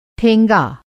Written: ठेंगा

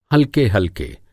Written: हलके-हलके

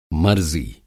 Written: मरज़ी

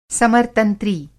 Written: समरतंत्री

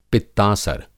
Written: पित्तासर

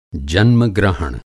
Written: जन्मग्रहण

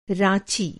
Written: राँची

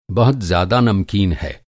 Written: नमकीन

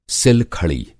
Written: सिल-खड़ी

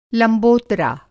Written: लंबोरता

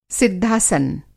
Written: सिद्धासन

